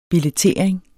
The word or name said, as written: billettering